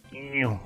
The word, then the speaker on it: GNU: Hay que pronunciar el bien el GNU, como dice Stallman, como es GNU.